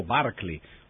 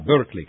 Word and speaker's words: Berkeley: Molti di voi avranno sentito parlare, per esempio, a scuola del vescovo Berkeley, o come si dice adesso all'americana, Berkeley, che però naturalmente è una pronuncia sbagliata.